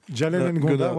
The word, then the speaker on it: Jalen Ngonda: Tu nous parlais tout à l'heure de Jela Ngonda, ça se prononce comme ça ? – Jalen Ngonda, ouais, il vient de Washington DC et c'est un des coups de cœur aussi du festival.